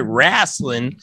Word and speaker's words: Wrasslin: You got to say it right. Wrasslin.